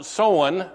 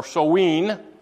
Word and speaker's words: Samhain: Further, they believed that on October 31st, and that's actually looks like Samhain, but they pronounce it Soan or Soween.